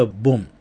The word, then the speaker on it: BOMB: えー、大質量インストバンド k y k y BOMB と書けば、まあ普通はボン。最後の B は発音しないんですけれども、